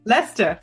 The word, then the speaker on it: Leicester: Lester.